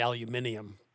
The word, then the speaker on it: aluminium: Well, first of all, the Brits say aluminium.